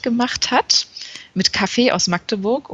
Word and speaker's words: Magdeburg: Genauso wie du es auch gut gesagt hast, dass es Magdeburg heißt und nicht Ja. – Magdeburg. – Ich habe gerade jemanden am Wochenende in der U-Bahn gesehen, der es genauso falsch ausgesprochen hat,